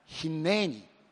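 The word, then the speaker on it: ヒ ネ ー ニ (הִנֵּנִי): 一番こう発音が近いカタカナ教えてってお願いしたら、ヒネーニ、ヒネーニでした。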